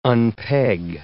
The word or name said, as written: unpeg